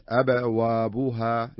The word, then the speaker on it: أبوابها: نلاحظ الهمز الأولى مهموزة ساكنة والثانية مضمومة، فالقلقلة تكون في الأولى وليست في الثانية، يعني تنطق بهذا الشكل، فتحت أبوابها. لاحظ أبا الثانية أبا وابوها بوها وليست أبوابو با الثانية، لا، الثانية تنطق باء مضمومة، تتم حركة الضمة بشكل واضح.